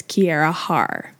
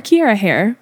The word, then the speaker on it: Kiarra Harr: That's spelled K-I-A-R-R-A-H-A-R-R. – Okay. – So the last name's Harris, but it's Kiara Harr. – Kiara Harr. – Or Kiara Hare, however you want to pronounce it.